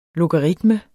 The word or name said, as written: LOGARITMER